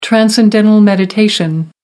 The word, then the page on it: transcendental meditation: American English